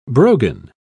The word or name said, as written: brogan